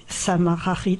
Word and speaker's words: Sama Xarit: Et donc après j'ai écrit 403 et puis enfin Sama Xarit, parce que Sama Xarit. – Sama Xarit, c'est comme ça que ça se prononce.